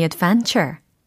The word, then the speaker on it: adventure: My adventure. My adventure. 그래요. ven 부분을 크게 발음해 주시면 됩니다.